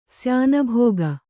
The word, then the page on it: ಶ್ಯಾನಭಾಗ: śyānabhāga